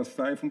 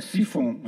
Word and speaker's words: siphon: Como é que fala em francês? Então é sifon.